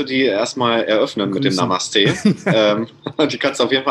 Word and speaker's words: Namaste: Sehr gut, Namaste.